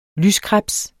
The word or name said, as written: lyskrebs